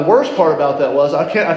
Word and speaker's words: Ai: Just like Joshua felt after the children of Israel lost the battle at the city of, spelled Ai, just pronounced I, the city of Ai.